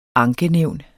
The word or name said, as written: ankenævn